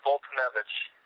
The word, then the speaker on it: Foltynewicz: Fultonevich.